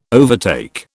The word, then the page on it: overtake: British English